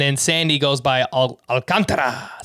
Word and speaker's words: Alcantara: I'm kicking myself because I was calling him Alcantara again. Like it's a pitcher after my own heart, and I can't even say his name right. Alcantara. It's Alcantara.